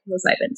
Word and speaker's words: psilocybin: And I wanted to explore microdosing, and I hope I'm pronouncing this correctly, psilocybin. Is that how you say it? – Yes. Yeah. – Okay, psilocybin.